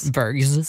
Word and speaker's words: Bergs: Hur säger man? Är det Bergs? – Bergs. – Som du ska uttalas. Bergs School of Communication. Du bara, Bergs School.